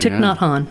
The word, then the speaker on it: Thich Nhat Hanh: Thich Nhat Hanh. Is that correct? – That is correct. Thich Nhat Hanh.